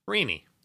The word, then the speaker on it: Reenie: How's it spelled? – R-E-E-N-I-E. – Rini. How would you say that? – Rini.